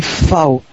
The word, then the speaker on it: VfB: On dit FAO, le VFB, je te dis.